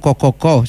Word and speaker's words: Co-co-co: Però rimane questo, insomma, sono altre 3.000 persone, 3.000 lavoratori e lavoratrici nuove assunti con Co-co-co, si legge così.